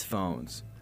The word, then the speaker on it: Fauxnz: The four-piece band spells its name F-A-U-X-N-Z. This week I mentioned to band members that the spelling could create some confusion about its pronunciation. – Yeah, that's true. – Yeah, it's phones.